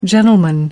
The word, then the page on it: gentleman: American English